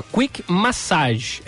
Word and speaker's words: quick massage: Aqui, ó, quick massage é, o, é, é, a pronúncia correta.